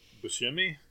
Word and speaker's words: Buscemi: Buscemi? – Yeah, I don't know where he comes in.